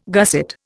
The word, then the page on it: gusset: Indian